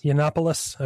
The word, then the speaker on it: Yiannopoulos: How do you say his last name? – Yiannopoulos.